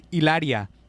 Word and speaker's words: Hilaria: She pronounced her name Ilaria, even though she's a white woman from fucking like the Bronx or some shit.